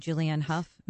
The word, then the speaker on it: Julianne Hough: And then after lunch, we had Julianne Hough.